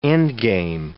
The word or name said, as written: endgame